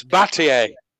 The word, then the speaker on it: Battier: Oh, it's Batai then. I don't know. It's spelled wrong. – It's Battier. – Battier. – Is it? Yeah. That's how I've heard people say his name.